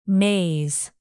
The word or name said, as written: maze